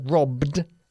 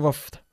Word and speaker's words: robbed: Y la B, las dos Bs de la palabra robbed se pronuncian de forma mucho más fuerte. – Robbed. – Rob, no roughed. Robbed. – Robbed. – Robbed, eso es.